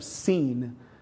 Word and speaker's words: Sin: or it's actually pronounced the Desert of Seen, like an S-E-E-N, as in though I seen something, you know, even though that's not right English, proper grammar.